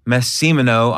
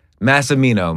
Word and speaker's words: Massimino: Massimino.